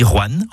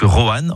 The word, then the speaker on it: Rouen: Vous avez remarqué comment je dis Rouen en une syllabe plutôt que Rohan en deux syllabes? C'est comme ça qu'on y dit dans la région.